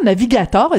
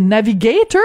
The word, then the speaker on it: Navigator: Est-ce que c'est navigator ou navigator? – Ça dépend. Si vous êtes francophone ou anglophone, vous le prononcez comme vous voulez. Ça se prononce dans les deux, dans les deux langues.